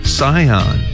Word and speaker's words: Sihon: 먼저 시혼을 영어로 이렇게 발음합니다. 잘 듣고 따라해보세요. Sihon, S-I-H-O-N, Sihon 이렇게 발음해 주시고 그모스는 C-H-E-M-O-S-H입니다.